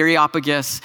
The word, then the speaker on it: Areopagus: The Apostle Paul, in his sermon at the Areopagus, and yes, I did say that right on the first time for those of you who have been tracking with us since the summer.